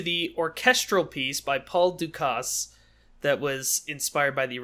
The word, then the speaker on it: Dukas: Oh, it says it's French. Like, it's French pronunciation. – Oh, it is. It's Dukas. Ha.